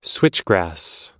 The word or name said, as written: switchgrass